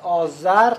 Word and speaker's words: آذر: یعنی آذر که اون موقع با زال تلفظ می شده برد شده است، آذر تلفظ شده ز ولی دیگه در خط ما ز نداریم، حفظ کردیم دیگه دال نشده.